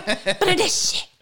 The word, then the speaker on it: Bernardeschi: Bernardeschi. – But anyway, he was, you know, and I didn't actually think, so Bernardeschi is sort of a off the Insigne kind of guy.